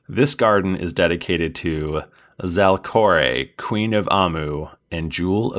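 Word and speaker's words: Zalcoré: That's Z-A-L-C-O-R, and then the E with an accent over it. I've decided that it's pronounced Zalkore.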